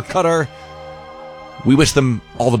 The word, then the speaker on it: Qatar: And we're getting conflicting because, look, I'm going to watch tonight with anticipation to listen to the announcers because I'm telling you, I used to say Qatar like a lot of people say, like rhymes with guitar. – Yeah. – Spelled with a Q, but it's pronounced Qatar. And then I started hearing more people say Cutter, like it's pronounced like Cutter.